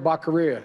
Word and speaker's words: Becerra: It was actually rhymes with diarrhea. – Javier Baccarilla.